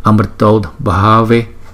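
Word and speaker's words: Amṛtodbhave: Nhưng mà chữ A và chữ U ghép lại thành chữ O, đọc là Amritod Bhave, Amrita Sambhave, Amrita Sambhave.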